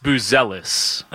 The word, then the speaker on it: Buzelis: And how do you pronounce that rookie's name? Badets? – Modest Buzelis. – Oh, I was close.